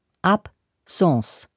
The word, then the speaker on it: Absence: Absence ist ein französisches Wort. Das Wort bedeutet Abwesenheit. Die Aussprache von Absence ist so: Absence.